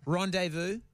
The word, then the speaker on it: rendezvous: Rendezvous is the one with the silent Z.